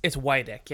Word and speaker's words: Widick: It's Widick, right? – Yeah, it's Widick, yeah. A lot of people say Widick or...